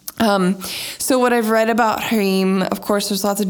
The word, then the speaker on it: Hrim: And today's mantra is Om Hrim, which is spelled H-R-I-M, and it's pronounced Hrim, kind of with a Hrim. And you might see it written around as H-R-E-E-M.